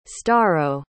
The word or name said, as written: storrow